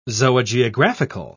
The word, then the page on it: zoogeographical: British English